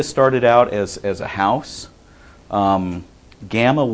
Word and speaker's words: gamma: It's gamma. It's the hard G sound. G as in go.